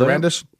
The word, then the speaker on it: Sarandos: Also, in the politically correct world, Netflix CEO Ted, how do you say his name? Sarandos? – Yeah. I'll go with Sarandos.